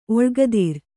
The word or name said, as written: ಒಳ್ಗದಿರ್